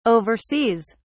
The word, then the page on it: oversees: British English